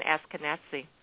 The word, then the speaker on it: Ashkenazi: Ashkenazi.